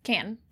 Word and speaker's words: Cannes: Cannes.